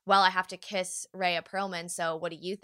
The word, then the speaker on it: Rhea Perlman: Rhea Perlman. – Rhea Perlman.